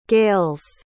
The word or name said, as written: gales